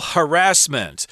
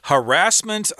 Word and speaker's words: harassment: Uh, there are two uh, acceptable pronunciations of this word Harassment, I believe, is more common in American English, but you do hear harassment sometimes. I think that's the word they tend to use in England harassment.